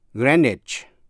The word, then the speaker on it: Greenwich: Biệt lệ là CH phát âm là CH như trong chữ Greenwich, G-R-E-E-N-W-I-C-H, Greenwich, ở London,